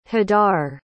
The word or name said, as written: hadar